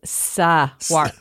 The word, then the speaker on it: Saguaro: It's not swa. It's Saguaro.